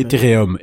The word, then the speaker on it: Ethereum: Alors évidemment, cet achat hein, qui s'est négocié à la hauteur de 120 Ethereum, ou Ethereum, comment vous prononcez Ethereum Ethereum, Ethereum.